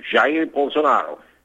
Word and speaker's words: Jair Bolsonaro: Okay, his name is Jair Bolsonaro, the correct pronunciation, Jair Bolsonaro.